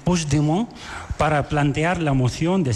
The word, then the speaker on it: Puigdemont: Me has preguntado antes, ¿cómo se dice este nombre? – Sí, voy a intentar. ¿Eh? – No, Puigdemont. – Puigdemont. – Vale. – Puigdemont. – Eso es. – Sí, lo dices como francés, así.